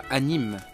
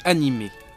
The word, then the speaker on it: animé: J'insiste sur la prononciation du « et ». On ne dit pas « anime », mais « animé ».